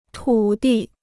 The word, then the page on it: 土地: tǔ di